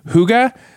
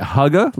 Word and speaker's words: hygge: Is it hygge? Like it's spelled H-Y-G-G-E, but it's pronounced, they said it rhymes with sugar.